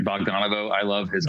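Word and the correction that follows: Bogdanove: I love John Bogdanov.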